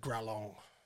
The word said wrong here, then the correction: Glaurung: or however you pronounce it. – Yeah, it was close. Who is the father of dragons? – Gralong. Gralong, or however you pronounce it. – Glaurung? – Yeah, that one.